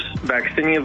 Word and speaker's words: vaccinia: So they have taken a uh, a virus, vaccinia virus, uh, is how you say it,